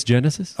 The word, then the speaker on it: Genesis: I believe it's Genesis. – Oh, okay. – Yeah. – This is, it's a soft G? – Yeah. – It's not a hard G? – Yep.